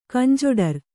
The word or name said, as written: ಕಂಜೊಡರ್